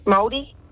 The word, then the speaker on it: Māori: Maori, Māori.